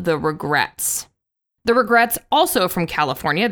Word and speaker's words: Regrettes: And I remember thinking Regrets is such a great name because it's The Regrets, but it's spelled E-T-T-E-S, like kind of a 60s girl group vibe.